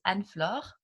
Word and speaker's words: Anne-Flore: Well, my first name is Anne-Flore, which is French-based.